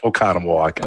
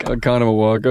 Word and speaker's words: Oconomowoc: Did I say that right? – Yep, Oconomowoc. – Oconomowoc. Okay, great.